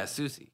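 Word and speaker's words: Soucie: Is it Sushi? Yeah, Sushi.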